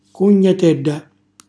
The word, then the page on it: cugnatella: calabrese